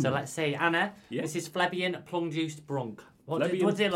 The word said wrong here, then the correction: plebeian: Plebeian plum.